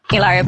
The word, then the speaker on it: Hilaria: So here's the video of her telling people how to pronounce Hillary for you guys. – Hilaria Baldwin. – One more time for the girls. – Hilaria Baldwin. – All right, great. Did we hear that? – Hilaria. – Hilaria. – Hilaria. Hilaria Baldwin. – Hilaria. – This feels like an episode of The Electric Company. – So that's how she pronounces Hillary. I didn't know that Hillary was Hilaria.